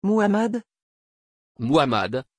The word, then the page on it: Muhammad: French